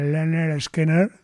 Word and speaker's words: Lynyrd Skynyrd: El disco se pronuncia Lynyrd Skynyrd, era el nombre del grupo que lo habían puesto por un profesor que tuvieron, algo así, es decir, el primero.